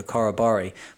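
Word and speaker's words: corroboree: We'd have these like weekly meetings and he said it was like a korobori, but I'm thinking he's pronounced it incorrectly. So it's korobori. It's got that rob in the center of it.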